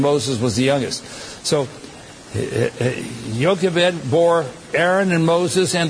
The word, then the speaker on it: Jochebed: It's spelled J-O-C-H-E-B-E-D.